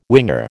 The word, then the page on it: winger: American English